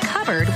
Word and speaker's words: cupboard: When we come back, why it's pronounced covered when it's spelled cupboard.